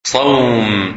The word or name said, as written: Sawm